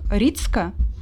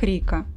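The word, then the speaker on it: Рика (立夏): Хотя вот там самое интересное, я удивлялась, что иероглифы пишутся в японском варианте как Рицка, а произносится как Рика.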